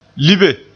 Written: libe

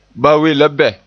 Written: we

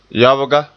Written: yabga

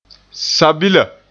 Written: sabila

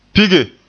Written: pige

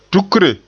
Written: tukre

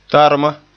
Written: tarma

